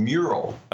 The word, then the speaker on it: Meural: Uh, that is a digital frame from one of our portfolio companies called Meural. – Okay. It's spelled M E. It's spelled M E U R A L.